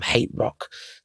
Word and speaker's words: Hate Rock: If you've read the band name, it's the HTRK, but it's pronounced Hate Rock.